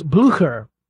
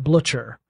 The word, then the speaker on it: Blücher: I'm told that the Brits actually pronounce it Blücher, which makes sense because it's spelled B-L-U with an umlaut, C-H-E-R, the correct pronunciation if you're going with the German or Prussian, as it turns out, is more Blücher, but they were Blücher.